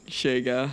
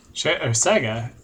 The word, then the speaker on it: Sega: Sega.